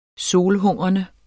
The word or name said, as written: solhungrende